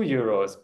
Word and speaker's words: Euro: Now you said 2 euros, but I'm asking 3 euros. – €3. – And make sure you pronounce it as Euro. It's like a word heute. You need to say ö-i. It's Euro.